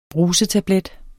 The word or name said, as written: brusetablet